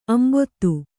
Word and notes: ಅಂಬುದ: ambuda

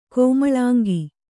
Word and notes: ಕೋಮಲಾಂಗ: kōmalāŋga

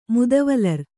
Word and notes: ಮುದವಲರ್: Kannada

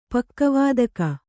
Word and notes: ಪಕ್ಕವಾದಕ: Kannada

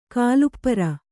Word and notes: ಕಾಲುಪ್ಪರ: kannada

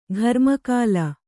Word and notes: ಘರ್ಮಕಾಲ: gharma kāla

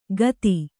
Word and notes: ಖತಿ: khati

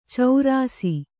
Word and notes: ಚಾರಿಸು: Kannada

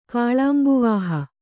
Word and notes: ಕಾಳಾಂಬುವಾಹ: kannada

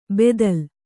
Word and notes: ಬೆದಲ್: bedal

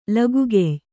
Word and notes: ಲಗುಗೆಯ್: Kannada

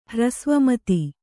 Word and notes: ಹ್ರಸ್ವಮತಿ: Kannada

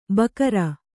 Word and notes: ಬಕರ: Kannada

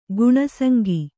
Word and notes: ಗುಣಸಂಗಿ: guṇa saŋgi

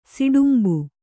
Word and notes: ಸುಟ್ಟುಂಬೆ: kannada

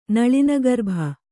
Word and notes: ನಳಿನಗರ್ಭ: Kannada

